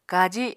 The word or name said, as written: kaji